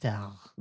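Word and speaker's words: Tár: It's pronounced Tar.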